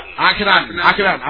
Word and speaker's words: Akhenaten: Akhenaten. I always reverse the K and the H.